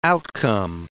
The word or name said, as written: outcome